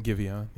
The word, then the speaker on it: Giveon: Giveon, is that how you say it? – Giveon, yeah.